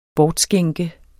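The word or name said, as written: BORTSKÆNKEDES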